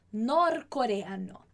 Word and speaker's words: norcoreano: Norcoreano. Aquí só atenção com essa pronúncia. Norcoreano. É só você lembrar. Então, da Coreia do Norte, norte-coreano, a gente fala norcoreano.